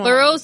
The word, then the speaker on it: Burroughs: B-U-R-R-O-U-G-H-S. – The Burrows. – Burrows. – ¿Qué significa Burrows? – Burrows es como las ajueras, ¿no? ¿Como las ajueras o qué será? Burrows es como... – Burrows es...